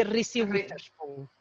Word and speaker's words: Reese Witherspoon: Jennifer Aniston y Reese Witherspoon.